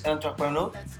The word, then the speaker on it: entrepreneur: Eh, el, término emprendedor del francés entrepreneur, ¿lo dije bien? – Sí, perfecto.